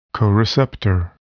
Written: coreceptor

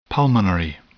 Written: pulmonary

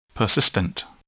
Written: persistent

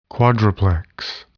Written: quadruplex

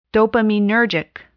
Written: dopaminergic

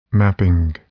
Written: mapping

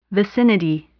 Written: vicinity

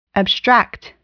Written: abstract

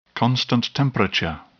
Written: constant temperature

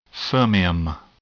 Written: fermium

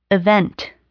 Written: event

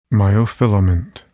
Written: myofilament

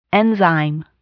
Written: enzyme

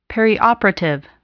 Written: perioperative

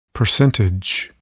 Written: percentage

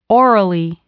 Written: orally

